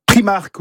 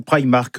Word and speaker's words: Primark: Primark ou Primark, peu importe comment on le prononce, les deux sont valables.